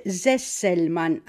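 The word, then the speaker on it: Sesselmann: Τώρα μπορεί να μην τον πω και σωστά αυτόν, αλλά νομίζω ότι προφέρεται Ζέσελμαν, αν η Ζάρα μου, είναι Σάρα γράφεται, αλλά προφέρεται Ζάρα, αυτός ο συγχαμένος προφέρεται Ζέσελμαν, με δύο νι στο τέλος, με δύο σου στη μέση, κατάλαβες, Ρόμπερτ, Ρόμπερτ.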